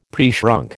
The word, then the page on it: preshrunk: American English